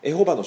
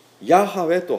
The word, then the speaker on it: ヤハウェ: で、これは今日では誤読であります。間違った読み方。エホバの証人ってありますけども、それは間違った読み方でずっとやってるんですね。間違いない、教義的にも間違ってますけども、読み方も間違っています。で、これをヤハウェと発音することができたのは近代の聖書学の一つの功績なんです。